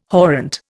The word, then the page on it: horrent: Indian